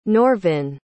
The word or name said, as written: norvin